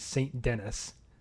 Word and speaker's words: Saint-Denis: But anyways, this is all in the city of Saint-Denis, as I've said before. The Americanized kind of pronunciation is Saint-Denis, I guess if you want to call it that.